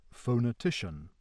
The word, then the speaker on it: phonetician: The English word phonetician, so pronounced, illustrates secondary stress on the first syllable and primary stress on the penultimate.